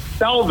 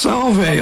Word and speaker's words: Salve: Did I say the name of that university correctly? Because it's new to me. – Yes, Salve is how it's pronounced. – Salve.